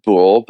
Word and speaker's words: boule: Well, technically it's pronounced boule, but because of drag, we'll go with boulet. – Really? Have I been mispronouncing it all this time? – Yeah, it's boule.